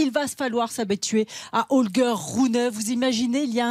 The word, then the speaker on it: Holger Rune: Il s'appelle Holger Rune. C'est bon, c'est la prononciation c'est est bonne. – Exactement.